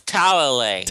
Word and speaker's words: Talalay: Director Rachel Talalay. Did I say that right? – It's Talalay. – Talalay. Okay, thanks. I knew it was a really fancy pronunciation.